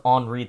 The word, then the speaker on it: Henri: As it's come to the coast, I was mistakenly calling it Henry for a whole week because I don't cover weather anymore, so I don't stay up to date with the pronunciation, so I just assumed it was Henry, and then I found out it was Henri this morning, and I was extremely embarrassed because it's French.